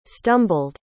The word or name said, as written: stumbled